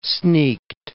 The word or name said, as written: sneaked